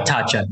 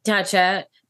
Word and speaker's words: Tatcha: You do work with Tatcha. Is that how you pronounce it? – Yeah. Tatcha. Yeah. – Tatcha.